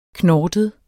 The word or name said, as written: KNORTEDE